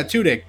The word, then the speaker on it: Tudyk: Yeah, Tudyk.